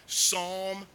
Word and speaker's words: Psalm: Psalm 73. Psalm 73, that's P-S-A-L-M. It's a silent P.